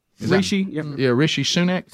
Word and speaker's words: Rishi Sunak: Rishi Sunak? Rishi Sunak.